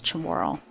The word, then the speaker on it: Chamorro: My mom is Samoan and my dad is Chamorro.